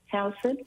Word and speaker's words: Towson: Towson? – Towson.